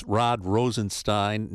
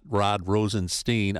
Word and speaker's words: Rosenstein: And just a side note, it is Rod Rosenstein, not Rod Rosenstein.